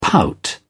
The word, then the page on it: pout: British English